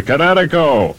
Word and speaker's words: Kinetico: Everybody knows that Kinetico is spelled K-I-N-E-T-I-C-O. – I thought the instructor said Connecticut. – Oh, son, you should have remembered kinetic as in kinetic energy with an O on the end. Kinetico. – Kinetico. Spell it K-I-N-E-T-I-C-O.